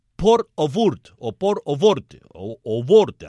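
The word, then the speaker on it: porte ouverte: Twitter, por ejemplo, abrió la etiqueta en francés Port au Vort, así se pronuncia en francés,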